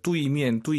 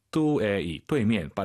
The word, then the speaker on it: 对面: 뚜이미엔, 뚜이미엔. 선생님, 알아듣죠? – 您能听懂? – 네, 안 예뻐요. 다시 한번. – 对面. – 뚜에이, 뚜이미엔. 빠르게 발음하시면 뚜이로 들리긴 하죠. 뚜에이, 정확히 발음하셔야 됩니다.